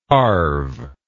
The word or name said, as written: harve